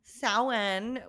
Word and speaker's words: Samhain: Samhain, which I always forget is spelled Samhain. – Yeah. Just what do they do with that M? – Where does it go? Silent M. – They flip it upside down and they pronounce it like a W.